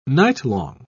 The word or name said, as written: nightlong